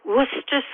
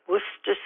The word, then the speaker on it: Worcester: I'm calling to tell you that I think the correct pronunciation for the sauce that you couldn't pronounce, or neither could Alia, is Worcester sauce. You don't have to say any of those C's, just the one, Worcester sauce.